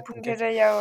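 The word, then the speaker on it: Puncak Jaya: Et en termes d'accès, que le plus difficile, c'est le Punca Jaya, c'est oui. Ça, c'est comme ça que tu le prononces. – Ouais, Punca Jaya, ouais.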